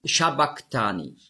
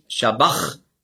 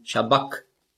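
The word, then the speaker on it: sabachthani: Und das CH im Griechischen, das ist der ein Buchstabe, das Chi, das hat man ausgesprochen so wie ein C hinten im Gaumen. K. Also nicht Shabach, sondern Shabak.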